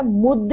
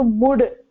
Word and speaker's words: मुद्: भगिनि एकक्षणं, तत्र मुद् इति धातुः, मुद् इति धातुः, न तु मुड्. मुद् डकारः, त त द, अतः डकारः, डकारस्य उच्चारणं मा भवतु.